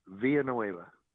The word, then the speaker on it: Villanueva: Villanueva.